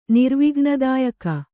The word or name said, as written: ನಿರ್ವಿಘ್ನದಾಯಕ